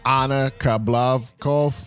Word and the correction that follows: Kabakova: I knew I was gonna fuck it up. That's why I didn't want to say it. – It's okay. So it's Anna Kabakova.